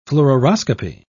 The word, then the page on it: fluoroscopy: British English